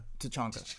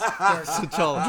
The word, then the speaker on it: T'Challa: T'Challa? Or Chadwick Boseman? – No, T'Challa. – It's T'Chanka. – T'Chanka. – Just kidding, that's a Rainbow Six. – T'Challa.